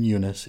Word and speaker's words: Eunice: Stan also says that the U in Eunice is long, so now we know how to say it, even though we've been saying it that way since Jump Street.